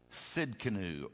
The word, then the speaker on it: Tsidkenu: So take your little pen, T-S-I-D-K-E-N-U. Jehovah, the T is silent. Sidkenu or Sidkenu is my best discernment of pronunciation.